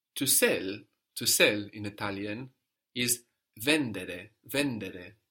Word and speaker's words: vendere: So some Italian verbs have the accent third from the end, vendere, but only a few. Mostly we have the accent on the penultimate syllable, on the second from the end, no, in the tu form.